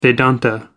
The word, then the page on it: Vedanta: British English